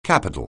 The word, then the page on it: capital: anglais